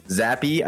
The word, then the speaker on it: Zappe: Zappe. – I didn't want to like say the E and make it be dumb.